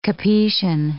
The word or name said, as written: Capetian